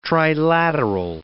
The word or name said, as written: trilateral